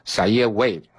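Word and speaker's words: Zaire Wade: 如果成长顺利的话，小叶韦德应该是念成萨耶· 韦德，会在 2020 年高中毕业。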